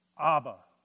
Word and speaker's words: Abba: We would pronounce the word Abba.